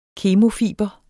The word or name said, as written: KEMOFIBRE